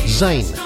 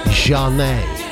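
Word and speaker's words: Zhané: So you'd look at that and go, ah, Zane. Nah. You pronounce the band Janet.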